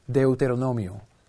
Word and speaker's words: Deuteronomio: Deuteronomio.